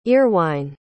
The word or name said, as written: earwine